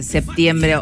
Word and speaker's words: septiembre: Incluso en registros cultos y formales, que refleja la simplificación a la pronunciación al relajar o no pronunciar la letra P. Sí, esa era la gran pregunta siempre, septiembre o septiembre.